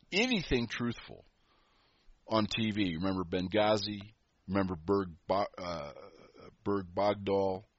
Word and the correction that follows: Bergdahl: I probably pronounced the name wrong. Bergdahl, right.